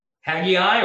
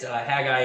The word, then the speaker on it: Haggai: Haggai, or Haggai, depending upon how you translate it. Haggai is actually probably the proper way to pronounce that, but I always grew up saying Haggai. So however you want to pronounce it, that's fine.